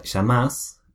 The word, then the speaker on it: llamás: sí, en la palabra llamás, al principio el sheísmo, que es la manera de pronunciar los dos L's, y al fin hay el voseo, en que se pone más énfasis. – Claro, se pone más énfasis en la última parte de la palabra, en la última sílaba.